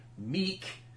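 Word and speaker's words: Miek: But so Korg and his buddy, the knife-handed little insect guy, Meek, who I wasn't aware that's how it was pronounced in the comics. It's spelled M-I-E-K-K. If it's Meek, that makes things easier for me.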